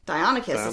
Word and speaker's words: Deinonychus: Deinonychus, that's one of them. That doesn't look like how you should pronounce that dinosaur name, but like when I was pronouncing it wrong, right. He corrected me. – Deinonychus? – Deinonychus is what he said.